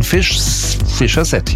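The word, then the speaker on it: Fischer-Z: Die Band war sehr lustig drauf und hat sich gedacht, wir nehmen einen Namen, der so ähnlich wie Fish's Head klingt. Ausgesprochen Fisher's Head, ja, also Fishkopf.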